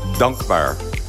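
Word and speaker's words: Dankbaar: I'm Hadass Kuznets at 1911 East Passyunk Avenue with the owner of Dunk Bar. Am I saying that right? – Dunk Bar? It's like a longer A. Dunk Bar.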